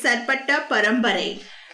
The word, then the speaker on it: Sarpatta Parambarai: Okay, so the name of the movie is Sarpatta Parambarai. – You know, you nailed it.